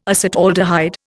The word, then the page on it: acetaldehyde: Indian